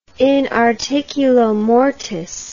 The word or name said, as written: inarticulomortis